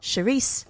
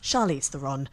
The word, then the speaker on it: Charlize Theron: Not Charisse, Charah, or Charon. It's Charlize Theron.